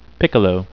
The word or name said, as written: piccolo